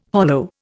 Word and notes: hollow: Indian

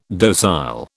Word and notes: docile: British English

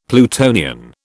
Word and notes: Plutonian: British English

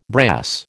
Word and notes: brass: American English

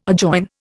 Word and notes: adjoin: Indian

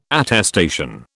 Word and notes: attestation: British English

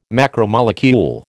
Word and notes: macromolecule: American English

